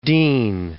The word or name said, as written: Deane